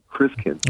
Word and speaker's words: Christkind: It comes from the German word Christkind, spelled K-I-N-D, but it's pronounced Kind, Christkind.